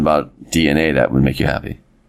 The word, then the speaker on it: DeNA: Oh, wait. Apparently it's pronounced DNA.